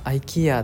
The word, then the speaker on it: IKEA: 第二ブリッジを渡って、スクのペナンの大陸の方に、なんと IKEA があるんですね。で、なんか僕もマレーシアに来て初めて知ったんですけど、 IKEA ってマレーシアというか、外国の方は IKEA じゃなくて、これ IKEA って呼ぶんですね。全然知らなくて、最初 IKEA 言って全然通じなくて、調べたらなんか IKEA って風に発音するって知って、